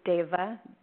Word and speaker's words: Devaa: It's my first name, which is Devaa, and it's spelled in a kind of different way.